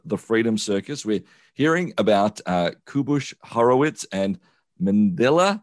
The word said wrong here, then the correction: Manya: No, I've got her name wrong. How do I say her name? – Manya. – There you go.